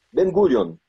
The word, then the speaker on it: Ben Gurion: É é, é, é, é, como é que pronuncia? Ben Gurion. – Ben, Gurion. Gurion.